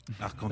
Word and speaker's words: Arkansas: Et donc la prononciation française du mot a été conservée. Et au fil du temps, cette prononciation est devenue un point de fierté qu'il fallait très bien prononcer. Donc il faut bien prononcer Arkansas.